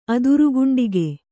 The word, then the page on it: ಅದುರುಗುಂಡಿಗೆ: Kannada